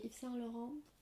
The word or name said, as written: Yves Saint Laurent